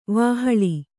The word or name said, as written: ವಾಹಳಿ